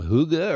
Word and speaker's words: hygge: I should tell people that it's spelled H-Y-G-G-E, so that's where it's tough to learn the pronunciation. And if you don't do the very often, it's tough to do that as well. So hygge.